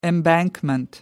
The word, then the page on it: embankment: American English